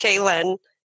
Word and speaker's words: Klyn: It looks like Klin, everyone. So if you're like, he mispronounced it. No, he did not. It is K-L-Y-N. Looks like Klin, pronounced Kaylin.